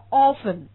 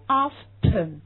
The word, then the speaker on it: often: 这个词是个双音节词，我们读成 often，但是你要知道这个词还有一个读音读 often，你看它有 often，那么它就两个读音，对不对？